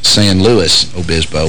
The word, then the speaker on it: San Luis Obispo: Also, got another listener who says that what I was calling San Luis Obispo is San Luis Obispo. Well, actually, says it's pronounced like St. Louis.